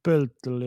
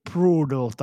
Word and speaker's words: Pöltl: Mä näin jossain semmoisen hyvän ääntämisohjeen tuohon pölttelin, niin it rhymes with turtle, eli niin kuin rimma kilpikonnan kanssa. Ei se kyllä sitä tee, mutta on se parempi kuin brutal.